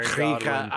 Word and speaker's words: Krige: Yeah, um, Alice Krieger is yeah, Krika. I just recently found out that that's how you pronounce her name, even though because as a Star Trek nerd, like she's the Borg.